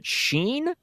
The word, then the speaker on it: Shein: Is it Shein? S-H-E-I-N? – Yes.